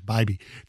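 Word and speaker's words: Bibee: You know, he was – I pronounce it Bibby, it's Bibby. Tanner Bibby, he was great.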